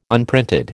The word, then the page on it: unprinted: American English